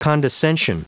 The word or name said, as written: condescension